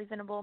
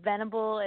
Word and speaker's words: Venable: It's just com or Ally Venable. My name is spelled A L L Y. and then Venable is V E N A B L E and then band.